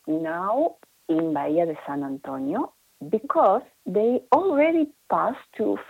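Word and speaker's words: Bahía de San Antonio: Hey, do you live close to the Bahia de San Antonio? Am I saying that right? – Yes, I am indeed in the Bahia de San Antonio.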